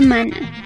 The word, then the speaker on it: mana: Entonces se escribe así como se escucha, mana.